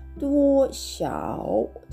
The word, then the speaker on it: 多少: 発音記号はですね「どうしゃお」というふうに